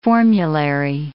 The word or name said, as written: formulary